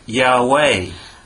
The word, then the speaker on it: Yahweh: But nowadays, many scholars think that this word might have been pronounced Yahweh.